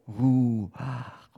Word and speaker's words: Ruach: Aber der Hebräer, wenn er das Wort Ruach ausgesprochen hat, dann hat das nämlich etwas nachgemacht, nämlich so, so, Ruach oder andersrum, Ruach, Ruach, Ruach. Das Wort Ruach sollte den Atem, bewegte Luft laut malen.